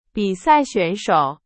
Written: 选手

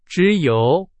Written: 只有

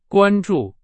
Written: 关注

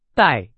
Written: 代

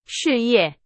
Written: 事业

